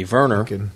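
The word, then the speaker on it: Wernher: Yeah, Werner von Braun, spelled with a W, but pronounced with a V, Werner.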